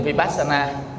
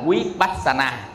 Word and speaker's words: Vipassana: Cái người mà đọc tiếng Pali ở miền Tây á thì đọc cái chữ Vi nè, đọc thành chữ W. Nên mai mốt mà quý vị nghe ta đọc chữ W, quý vị cũng đừng có thắc mắc nha. Ta là Vipassana, mình đọc là Vipassana, ta Vipassana, mình là Vipassana, ta là Vipassana. Tức là ta đọc thành chữ W này nè. Còn mình đọc thành chữ V này.